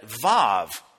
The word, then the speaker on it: Vav: When I read it, Vav, Hebrew, modern Hebrew grammar spells that letter W-A-W. It sounds like a V.